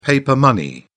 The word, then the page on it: paper money: British English